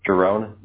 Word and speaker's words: Giron: Giron, G-I-R-O-N.